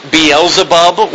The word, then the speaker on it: Beelzebub: When they're worshiping Molech, when they're worshiping Ashtaroth and Rimphan and Baal Zebub was a god that they were worshiping, which in the New Testament is pronounced Beelzebub, which is Satan, and Jesus refers to as Satan.